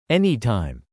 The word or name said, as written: anytime